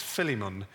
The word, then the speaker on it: Philemon: And we find him in a book of, I've been corrected on this, it's Philemon, which it's a book in the Bible, it's just left of Hebrews.